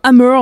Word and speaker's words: AMR: Busan sur Back FM avec un extrait du nouvel album Hammer, on va prononcer comme ça, ça s'écrit AMR si vous le cherchez, qui est sorti le 4 mai.